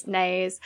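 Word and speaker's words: Naas: He's in Nass? – Nays, of course.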